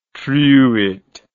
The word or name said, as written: truite